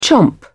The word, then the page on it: chomp: British English